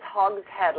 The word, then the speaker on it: Hogshead: It's Hogshead, like the head of a hog.